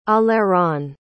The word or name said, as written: aleron